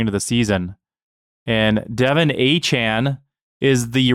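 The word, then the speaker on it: Achane: And by the way, we've been saying his name wrong this entire time. I was watching Red Zone A-chan. – This week. Yep. And they kind of corrected us all.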